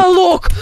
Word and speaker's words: каталог: Каталог! Тихо! Тихо, мне сейчас станет плохо правильно говорить каталог, Витюша.